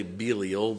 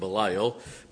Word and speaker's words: Belial: Some say Belial, but I really think it's pronounced Belial.